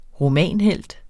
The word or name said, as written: romanhelt